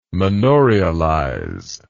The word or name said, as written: manorialize